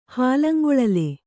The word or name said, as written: ಹಾಲಂಗೊಳಲೆ